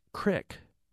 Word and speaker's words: creek: Tim says via email, I have friends in Illinois who pronounce creek as crick.